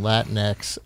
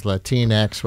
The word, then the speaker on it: Latinx: You're saying Latinx, I've heard it elsewhere as Latinx, rhymes with Kleenex.